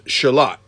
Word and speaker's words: Charlotte: So we go to the closest Walmart, which is in Charlotte, which is spelled like Charlotte, but, well, North Carolina. – Charlotte. – But it's pronounced Charlotte.